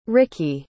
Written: rikki

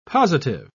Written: positive

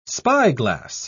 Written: spyglass

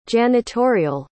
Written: janitorial

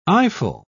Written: eyeful

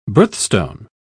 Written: birthstone

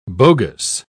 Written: bogus

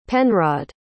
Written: penrod

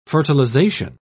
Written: fertilization

